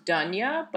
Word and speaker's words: Dounia: Her name's pronounced Dunya, but it's spelled D O U N I A.